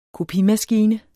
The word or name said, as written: kopimaskine